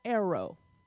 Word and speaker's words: Aarrow: One name is spelled A-A-R-R-O-W. Arrow. Like Aaron, but Arrow.